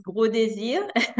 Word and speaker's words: Gros Désir: I hope I don't massacre her last name. It is Gros Désir. – Yes, it's complicated to pronounce. It's Gros Désir. It's a French name.